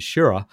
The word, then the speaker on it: Schirra: took flight with Stafford and Shira, who played the tune of Jingle Bells, a blues harp space hurrah. You have to work a little bit to get it to rhyme with Wally's name.